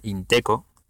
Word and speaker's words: Inteco: Y nada, entonces si alguien está interesado en saber cómo funciona Bitcoin, pues tiene el PDF este de Inteco, que se, se escribe así como suena, Inteco, I-N-T-E-C-O,